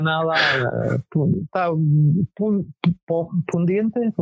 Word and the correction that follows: pudiente: ¿cómo se dice? Pundiente. Ya se me han ido. Pudiente. Pudiente. Viste que tantos años.